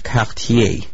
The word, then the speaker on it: Cartier: Cartier's first voyage. – Okay. First of all, it's pronounced Cartier. Okay, that's number one. Can you say that? Cartier.